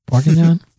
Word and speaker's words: Bourguignon: How do you say that? Bourguignon? – Basically like that.